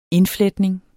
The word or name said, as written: indfletning